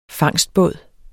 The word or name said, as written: fangstbåd